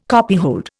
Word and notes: copyhold: Indian

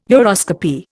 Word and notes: uroscopy: Indian

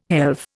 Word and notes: helve: Indian

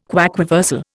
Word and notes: quaquaversal: Indian

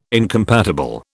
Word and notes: incompatible: British English

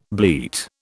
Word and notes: bleat: British English

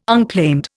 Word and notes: unclaimed: Indian

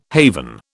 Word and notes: haven: British English

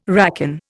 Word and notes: racon: Indian

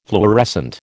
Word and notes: fluorescent: American English

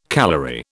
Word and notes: calorie: British English

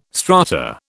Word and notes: strata: British English

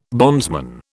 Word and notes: bondsman: British English

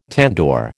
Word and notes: tandoor: American English

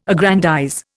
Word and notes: aggrandize: Indian

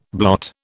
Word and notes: blot: British English

